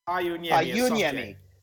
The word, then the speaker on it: Pajuniemi: Is it Pajuniemi or Pajuniemi? Is there a hard J or a soft J? Pa Unyemi.